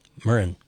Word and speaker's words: Muirenn: Looking forward to whatever comes next from Murren Bradley. And interestingly enough, her name is spelled Muirenn. Murren.